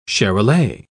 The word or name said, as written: charolais